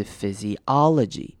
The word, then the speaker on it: physiology: 好，然后这个字的重音在 ology 的 O 的那个声音上，所以是 physiology。